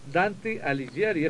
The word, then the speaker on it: Dante Alighieri: Dante Alighieri, es como algo así es como se pronuncia.